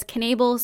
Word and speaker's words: Knabel: And I did look up how to say it. It is Knabel. Her family says Knabel, so I would assume that they know how to pronounce their own last name.